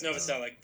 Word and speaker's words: Novoselic: It's Novoselic, but yeah. It's, Novoselic? I'm sorry. Yeah. It looks like a Serbian name. You pronounce the Serbian names with the chich.